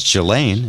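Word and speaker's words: Ghislaine: I learned how to pronounce Jelaine. It's like a G with a Elaine on the end of it.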